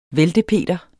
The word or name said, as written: væltepeter